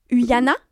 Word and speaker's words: Uhaina: Je parle basque couramment, donc dis-moi les lettres et je te le lis. – U-H-A-I-N-A. Uyana. – Uyana, je pense.